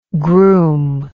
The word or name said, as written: grume